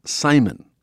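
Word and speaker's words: CIMON: They call it Simon, spelled with a C.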